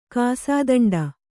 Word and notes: ಕಾಸಾದಂಡ: kāsādaṇḍa

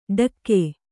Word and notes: ಢಕ್ಕೆ: Kannada

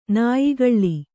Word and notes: ನಾಯಿಗಳ್ಳಿ: Kannada

